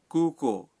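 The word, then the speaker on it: کو کو (空港): کو کو کے معنی ہے ہوائی اڈا کو کو میں کو اور کو دونوں حصوں کا تلفظ لمبا ہے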